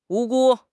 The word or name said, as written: 无辜